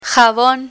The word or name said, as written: jabon